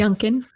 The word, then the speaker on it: Youngkin: Youngkin.